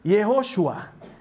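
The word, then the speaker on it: Yehoshua (יהושע): Su nombre en hebreo se pronuncia Yehoshua, que tiene esa primera raíz de Yahweh y que continúa con es el Salvador.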